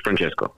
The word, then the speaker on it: Francesco: And now I learned the correct pronunciation of your first name with the correct accent in there as well. – Yes, Francesco. Yeah.